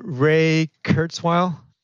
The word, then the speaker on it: Ray Kurzweil: It's Ray Kurzweil, KURZWEIL.